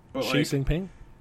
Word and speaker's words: Xi Jinping: Xi Jinping? – Yeah, Ping. Like, he... I forgot. I only knew his first name. I never heard his last one. – Xi Jinping, actually.